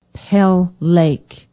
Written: pell lake

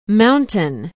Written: Mountain

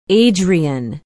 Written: adrian

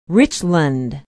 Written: richland

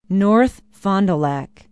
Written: north fond du lac

